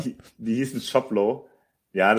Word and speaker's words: Shoplo: Ähm, um dann wirklich Shoplo. – So Shoplo. – L-O, okay, nicht L-O-W. Ja, aber es spricht sich wie LO.